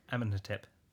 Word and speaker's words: Amenhotep: Maybe it isn't Amenhotep.